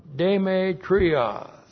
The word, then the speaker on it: Demetrios: The proper noun Demetrios. That's how it looks in the Greek. That's D, long E, M, long E, T, R, I, O, S.